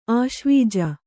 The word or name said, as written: ಆಶ್ವೀಜ